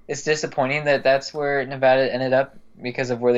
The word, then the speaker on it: Nevada: Yes, Nevada. That's the correct way to say it, not Nevada.